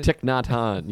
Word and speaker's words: Thich Nhat Hanh: Thich Nhat Hanh, yeah.